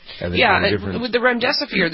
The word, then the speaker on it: remdesivir: All right, so then the one other thing, the National Institute of Health, says drug trials are showing that this remdesivir, is that how you pronounce it? – Remdesivir. – Mm-hmm.